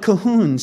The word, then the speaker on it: Cahun: Is it Cahun or Kuhn? It's C A H U N. Second syllable so tight and small it's not even really there. Claude Cahun.